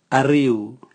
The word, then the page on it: ariu: Raga